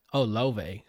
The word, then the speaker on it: Laufey: Well, you can go ahead and start us off with Laufey's. Lovey. Sorry. – Apparently, I saw after I heard her album, they just recommended me a video on YouTube of how to say her name. It's Icelandic.